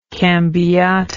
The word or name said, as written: cambiata